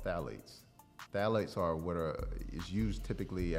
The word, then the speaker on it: phthalates: So when you turn it on the label and you see phthalates, it starts with a P, mm-hmm. the P is silent.